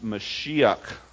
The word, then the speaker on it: Mashiach: It's the word pronounced Mashiach. We pronounce it Messiah in English.